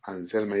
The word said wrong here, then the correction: Anserma: dije Anserma. – Anserma, sí, con R.